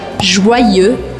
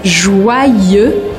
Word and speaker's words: Joyeux: Essa palavra se escreve com Y e o Y eu considero como se fossem dois Is. Então o primeiro I vai fazer o som Ua, Joa, e o segundo I eu vou pronunciar Joyeux, Joyeux Noel.